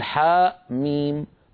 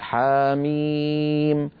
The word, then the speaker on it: حم: يعني لا نقول حاء ميم بل نقول حاميم، لا نقول طاء هاء بل نقول طاها.